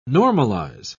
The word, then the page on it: normalise: British English